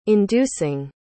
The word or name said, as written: inducing